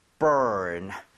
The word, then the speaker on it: burn: 얘도 발음할 때 R 살아있죠. – 예. – 그래서 아까 warn처럼 혀를 당기고 은 하고 아하. – finish 하셔야 돼요. 자, 해볼게요. burn.